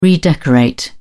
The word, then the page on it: redecorate: British English